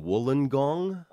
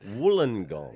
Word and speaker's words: Wollongong: and then I'm doing Wollongong.